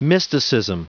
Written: mysticism

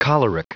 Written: choleric

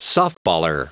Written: softballer

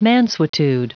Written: mansuetude

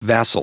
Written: vassal

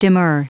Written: demur

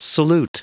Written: salute

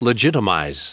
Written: legitimize

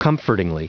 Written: comfortingly

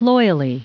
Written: loyally

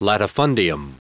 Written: latifundium

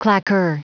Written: claqueur

